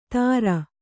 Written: ತಾರ

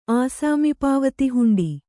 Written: ಆಸಾಮಿಪಾವತಿಹುಂಡಿ

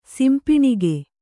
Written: ಸಿಂಪಿಣಿಗೆ

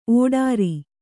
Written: ಓಡ್ರ

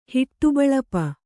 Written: ಹಿಟ್ಟುಬಳಪ

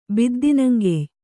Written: ಬಿದ್ದಿನಂಗೆಯ್

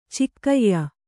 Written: ಚೊಕ್ಕೆಯ

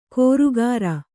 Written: ಕೋರುಗಾರ

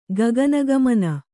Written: ಗಗನಗಾಮಿನಿ

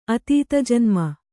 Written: ಅತೀತಜನ್ಮ

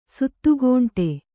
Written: ಶತಖಂಡ

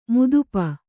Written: ಮದಿಪು

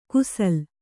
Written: ಕುಸಲ್

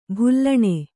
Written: ಬೆಲೂನು

